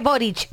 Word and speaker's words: Boric: Ahí está, se pronuncia Boric, ¿verdad, Angie? – Así es.